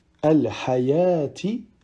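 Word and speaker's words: al-hayati (الحيوة): Ensuite, dans ces deux cas, on ne prononcera pas le waouh, tel que dans au-dessus, al-hayati, ou bien as-salata.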